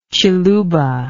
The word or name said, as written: ciluba